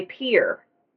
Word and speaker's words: Pierre: I pronounced it Pierre. It's actually Pierre. – Most people who are out of the state of South Dakota pronounce it Pierre. It is Pierre by sense because it is founded by a French founder and they named it after him.